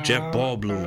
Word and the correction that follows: Jeff Goldblum: Jeff Goldblum? – Yeah, I said it wrong.